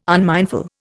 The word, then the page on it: unmindful: Indian